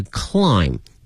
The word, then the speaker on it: climb: 同时同学们记住了英文拼写的一个规律呢，如果说是以 MB 结尾的话呢，很多情况下这个 B 是不发音的，比如说爬这个词它的发音是 climb，但是呢它的拼写里面会有一个 B C-L-I-M-B，因此呢同学看到这个 MB 结尾的单词的时候呢一定要小心，很多情况下这个 B 是不发音的。